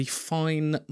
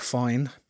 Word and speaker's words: Fyne: By the way, M Vapes is changing their name in the next few weeks, and they are going to be Fine Mods, as in Loch Fine in Scotland. So it's spelled F-Y-N-E.